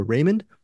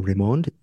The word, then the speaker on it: Raymond: I would have always called her Raymond, but it's Tanya Raymond.